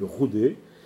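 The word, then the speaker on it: rodet: Ça s'écrit rodé, mais en occitan, il faut prononcer le roudé, qu'elle soit verticale ou horizontale.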